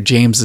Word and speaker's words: Jaymz: The man who makes the people dance, Dr. Jaymz is here for a talk.